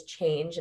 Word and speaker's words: CHNGE: One is Change, and it's a clothing company. It's spelled CHNGE.